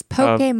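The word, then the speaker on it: Pokémon: There's an accent on the E on the cards. So that leads you to think Pokemon.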